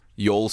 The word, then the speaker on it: Youell: Spell it for me one more time. – Y-O-U-E-L-L. – Yoel Sweeney? – Yes.